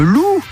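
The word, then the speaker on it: Lu: Pour gagner vos invitations, jouons avec le loup. Alors Eric Lou, ça s'écrit L-U, il est d'origine asiatique, mais bon, ça se prononce comme l'animal, loup.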